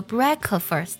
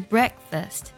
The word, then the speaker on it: breakfast: 我们不要读作 breakfast，而是 breakfast， break，基本上是听不到这个 k 的音。